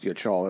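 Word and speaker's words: yachal (יחל): That's how it's pronounced, yachal. That's not how it's spelled. Trust me, it's Hebrew, right?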